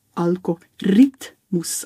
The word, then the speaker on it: Algorithmus: Und jetzt aber, zum wieso ist nochmal fixen, eigentlich die korrekte Aussprache wäre Algorithmus. Also eigentlich auf die Algorithmus, also auf die dritte Silbe betonen und das I sagen und nicht das Ü.